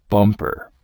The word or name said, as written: bumper